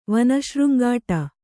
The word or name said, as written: ವನಶೃಂಗಾಟ